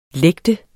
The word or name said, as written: lægte